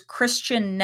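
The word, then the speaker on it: Kristin: So the woman is Christian Neff, but Christian is spelled Kristen. So I think it's KristenNeff, but Kristen is pronounced Christian.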